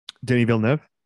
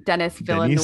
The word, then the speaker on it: Denis Villeneuve: Denis Villeneuve. – So you're going to say it like that, which is correct, but I'm going to say it like American Denis Villeneuve. – Denis Villeneuve, yeah.